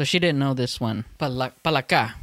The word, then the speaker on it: palaka: Is it palaka? Palaka.